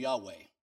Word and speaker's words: Yahweh: You would say Y-H-V-H. But in Hebrew, it's pronounced Yod, He, Va, He. Now, some pronounce that name Yahweh.